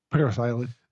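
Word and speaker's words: Parris Island: Paris Island. – They have an extra R, so I didn't know if it was pronounced differently. – At least that's the way it's pronounced in the Billy Joel song, so I just assumed.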